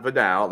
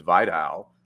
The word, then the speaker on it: Vidal: So it's not Vidal, like Vidal Sassoon. It's Vi. It's got a long I. So, you know, I heard that from the player. So it's Vidal.